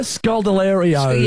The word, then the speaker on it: Scodelario: It's Kaya Scodelario.